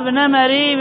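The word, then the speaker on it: نَمَري: قال الصواب نمري بفتح الميم.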